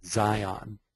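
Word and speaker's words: Dzyan: Zan. Some pronounce it Zion.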